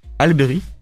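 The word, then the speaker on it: Halle Berry: On notera par exemple Bruce, réalisé et avec dans le rôle titre Elberry, Alberry, là qu'on prononce. Alberry.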